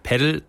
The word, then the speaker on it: Padel: Heißt das jetzt eigentlich Pedal oder Padel? – Ich würde sagen eher Pedal, aber man kann es auch Padel aussprechen. Es geht beides.